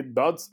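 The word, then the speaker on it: Dodds: I never get to pronounce the last name. Kent C. Dots, I think. – Yeah, that's it. Nailed it.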